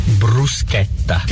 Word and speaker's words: bruschetta: It's not bruschetta. – No. – It's bruschetta. – Oh. – Bruschetta.